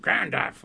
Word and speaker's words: Gandalf: But I just got him to ask him, and he's Gandalf, of course, rhymes with half, like half a sixpence.